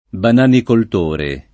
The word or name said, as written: bananicoltore